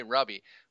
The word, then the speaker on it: Rubby: I will say Rubby, but when I'm on, you know, radio or podcast, the correct pronunciation is Ruby, so I try to be correct.